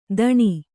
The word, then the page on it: ಧಣಿ: Kannada